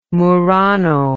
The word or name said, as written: Murano